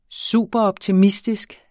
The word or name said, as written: superoptimistisk